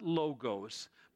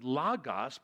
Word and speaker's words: logos: It's not logos, but logos, because it's short O's and not long O's.